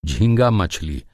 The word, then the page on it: हिल्सा: Hindi